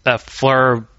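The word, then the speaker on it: Fliehr: Fleur, basically pronounced it not as a Flair.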